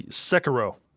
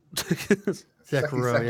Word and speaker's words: Sekiro: Oh. Yeah. Sekiori? Fuck up the name of it. Sekiro. – Sekiro, yeah. I was wondering why you're pronouncing it like that. I was like, did he add letters?